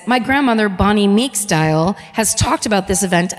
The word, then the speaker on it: Diehl: this is JT, I'm assuming it's pronounced Dial, D-I-E-H-L, 74.